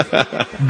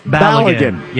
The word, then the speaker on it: Balogun: Hey, how do I pronounce that again? Flo Balogren. Balogren. – Balogun. – How is it? – Balogun. Yeah.